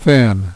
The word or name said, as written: thin